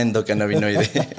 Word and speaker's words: endocannabinoide: Y bueno, sí, está perfecto, se pronuncia medicina endocannabinoide.